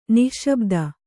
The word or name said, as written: ನಿಶ್ಶಬ್ದಿ